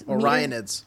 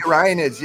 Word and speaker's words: Orionids: Orionids. – Oh, thank you. – The Orionids, yes.